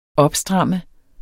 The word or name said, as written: opstramme